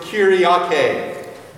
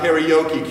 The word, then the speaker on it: kyriake: The word church comes from the Greek word kyriake, pronounced kirke in German. Kyriake, not karaoke.